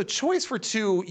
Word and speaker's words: Choice 42: Now, for people who look it up, it's spelled like Choice for number two number. So it'll be like Choice 42, which is what I first thought when I read it.